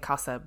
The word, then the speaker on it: Xhosa: Also, Xhosa is pronounced with that lovely click in the word, so I can't do that. So I'm saying Xhosa, but just in case anybody is annoyed by my pronunciation, I did my best.